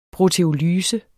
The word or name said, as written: proteolyse